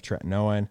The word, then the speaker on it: tretinoin: What are your thoughts on retinol versus like a retinoid, like retin-A, or like what's the generic, the tretinoin? Am I saying that right? – Yeah, tretinoin.